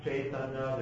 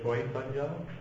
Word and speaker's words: Chaitanya: So there's Chaitanya, there's Chaitanya, there's Choitanya. If you're from Bengal, it's more, those A's become O-ish, so Choitanya. Like if you go to the big park in Calcutta, although it's spelled M-A-I, it's Moinan.